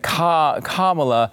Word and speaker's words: Kamala: And then I realized there's only one way to really understand Kamala, and it's by just remembering that Kamala rhymes with the reaction to Kamala, which is like, nah.